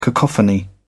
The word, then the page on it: cacophony: British English